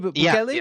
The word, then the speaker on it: Bukele: I asked you to pronounce his name for me. I think I pronounced it right. Correct? Nayib Bukele. – Yeah.